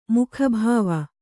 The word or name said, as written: ಮುಖಭಾವ